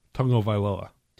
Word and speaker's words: Tagovailoa: And Tua Tagovailoa. – Well, it's tongue, actually. Tagovailoa. – Tagovailoa? – Yeah. It's not spelled like that, though.